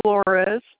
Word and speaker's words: Flores: Amy, A-I-M-E-E. Flores, F-L-O-R-E-S.